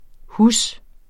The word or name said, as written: hus